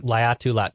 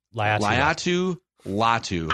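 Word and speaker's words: Laiatu Latu: Edge rusher, I believe it's pronounced Laitu. Is it Laitu Latu is his name. – Laitu Latu, yeah. – Laitu Latu.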